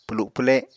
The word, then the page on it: pwlu pwle: Apatani